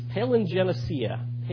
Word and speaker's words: palingenesia: The Greek word for regeneration, if I can pronounce it properly, is palingenesia.